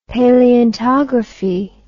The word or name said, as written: paleontography